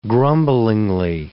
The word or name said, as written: grumblingly